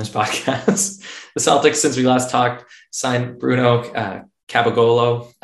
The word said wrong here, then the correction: Caboclo: No, that's what you call Capogolo. You're doing a Sopranos impersonation. It's Caboclo. – Caboclo.